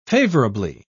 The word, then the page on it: favourably: British English